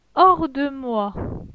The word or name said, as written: Hors de moi